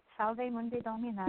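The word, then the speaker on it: Salve Mundi Domina: Share with us about Solve Mundi Domina. Did I pronounce that right or am I off on this? – Yes, you got it. Solve Mundi Domina.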